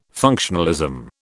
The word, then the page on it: functionalism: British English